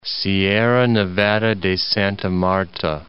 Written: Sierra Nevada de Santa Marta

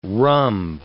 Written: rhumb

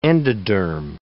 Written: endoderm

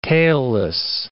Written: tailless

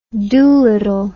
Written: Dolittle

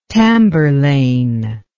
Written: tamburlaine

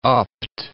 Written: upped

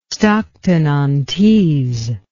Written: stockton on tees

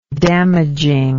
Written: damaging

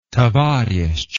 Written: tovarishch

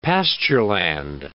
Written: pastureland

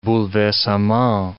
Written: bouleversement

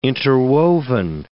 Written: interwoven

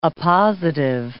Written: appositive

